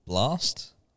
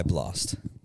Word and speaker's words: BLXST: I usually play local artists, but this track that I found this track recently, and it's by an artist named Blast. His name is BLXST. I think it's pronounced Blast or Blast or whatever.